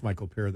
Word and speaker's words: Paré: Michael Paré? – Michael Paré. – I think it's Michael Paré that is what I remember his name is. – Yeah. – How they pronounced it.